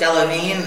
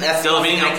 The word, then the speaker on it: Delevingne: I can't pronounce it. What was it? – Delavine. – Delavine, okay. The premise of the show, here it is.